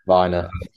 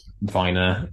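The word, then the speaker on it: Vyner: I think they probably just need a bit of an upgrade on the third centre back, Vinea. That might be how you pronounce it. – Viner. – Viner.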